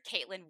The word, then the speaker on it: Kaitlyn: I am on Facebook under Caitlin Wilby.